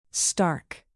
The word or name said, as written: stark